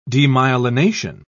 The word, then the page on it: demyelination: British English